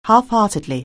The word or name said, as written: half-heartedly